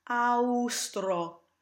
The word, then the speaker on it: austro: In words like this, I also recommend thinking truly phonetically, austro, soave.